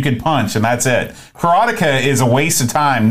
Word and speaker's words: Karateka: And Karateka also came out, which is the real pronunciation, Karateka came out.